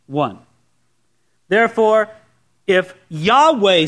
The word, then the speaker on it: Yahweh: It's in those small capital letters, which means it's the Hebrew word Yahweh sometimes erroneously pronounced Jehovah, but it's the Hebrew word Yahweh, the sacred name of God, the tetragrammaton.